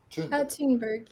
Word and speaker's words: Thunberg: So Amy Goodman says it Thunberg. I don't know how to pronounce it. Thunberg? – Thunberg, yeah. Greta has been very supportive of so many activists.